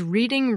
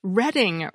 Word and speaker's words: Reading: A listener named John Commonets from Pennsylvania told me that the property in Monopoly that my family and my husband's family have always pronounced as Reading Railroad is actually pronounced Reading Railroad.